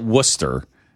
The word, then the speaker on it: Worcester: They call it Worcester. They drop out all the consonants and just make everything vowels.